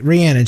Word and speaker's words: Rhiannon: Rhiannon. I said it right. Rhiannon. She spelled it phonetically for me.